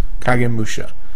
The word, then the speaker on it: Kagemusha: Katamusha? – Kagemusha? – Kagemusha? – Yeah. – Okay. – No hard G's in Japanese. – Kagemusha. Okay. Musha. Kagemusha.